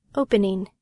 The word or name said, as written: opening